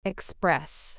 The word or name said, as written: express